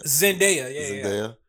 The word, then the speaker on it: Zendaya: Zendaya. – Zendaya.